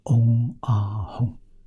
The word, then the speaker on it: 嗡阿吽: 啊，我们在很多佛像啊，画的佛像，佛像头顶上有圆光，圆光上面写了有三个字，有梵文的，有藏文的，也有中文的，这三个字发音叫嗡阿吽啊，嗡是身，阿是口，吽是意啊，就是三业清净啊，三业圆满的清净是佛，这皆无过失啊。